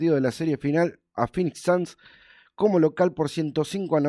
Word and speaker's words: Phoenix Suns: Eh, le ganaron a Phoenix, Phoenix Suns, así me dice.